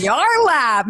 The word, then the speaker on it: Yarlap: She is a pelvic floor enthusiast and the founder of, am I saying this right? Yarlap? Yes, it is. Yarlap.